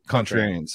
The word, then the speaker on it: Contrarian: Like, was it Centurion or how do you say that? And then Contrarian. – Contrarian. Sorry. Yeah.